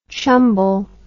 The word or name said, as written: chumble